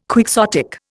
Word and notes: quixotic: Indian

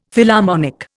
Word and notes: Philharmonic: Indian

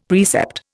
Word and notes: precept: Indian

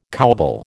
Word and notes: cowbell: American English

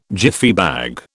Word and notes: Jiffy bag: British English

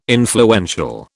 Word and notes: influential: British English

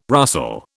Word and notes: rustle: British English

